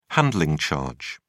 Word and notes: handling charge: British English

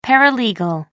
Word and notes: paralegal: American English

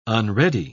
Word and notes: unready: British English

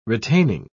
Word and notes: retaining: British English

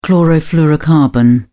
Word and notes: chlorofluorocarbon: British English

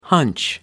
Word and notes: hunch: American English

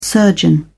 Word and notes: surgeon: British English

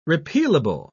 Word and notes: repealable: British English

